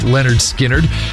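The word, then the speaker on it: Lynyrd Skynyrd: This day in 1973, Leonard Skinner released their debut album, pronounced Leonard Skinner.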